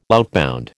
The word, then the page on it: outbound: American English